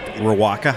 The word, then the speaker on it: Riwaka: Uh, Riwaka hops. Probably pronunciation error there. I think you nailed it.